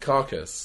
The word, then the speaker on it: Karkus: By the carcass. – Which apparently is spelled K-A-R-K-U-S, but when watching it, I don't see any letters, so I just assumed it was, like, a dead... – Carcass. – Carcass, yeah.